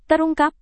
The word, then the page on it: terungkap: indonesian